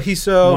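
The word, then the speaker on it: Risso: Because I believe he's Brazilian, and they pronounced the R as an H. – Got whatever. – It. – Eduardo Hisso.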